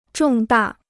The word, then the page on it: 重大: zhòng dà